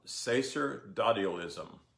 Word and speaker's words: sacerdotalism: It's S-A-C-E-R-D, that's like sacred, O-T-A-L-I-S-M, and it's pronounced sacerdotalism.